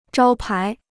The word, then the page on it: 招牌: zhāo pai